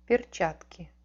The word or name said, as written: perchatki